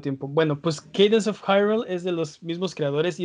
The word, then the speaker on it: Hyrule: Candance of Hillary. ¿Cómo, cómo lo pronuncia? – No, no, no, no. Cadence of Hyrule.